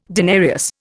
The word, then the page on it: denarius: Indian